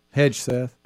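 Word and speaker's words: Hegseth: Hedgeseth.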